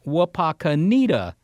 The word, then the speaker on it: Wapakoneta: All right, and we said Wapakoneta. Well, doggone it, did we offend anyone? – Not that I'm aware of. My cousin says that that is actually more accurate to the Native American pronunciation.